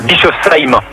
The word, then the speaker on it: Bischofsheim: Bischofsheim.